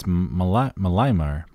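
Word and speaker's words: Mălaimare: Mihai Malamar Jr. I think you're getting farther away. – No, it's Malamar.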